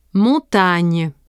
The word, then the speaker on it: montagne: For words ending in G-N-E, make sure you don't pronounce the final E. – Mieux. Montagne.